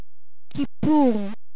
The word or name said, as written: kipur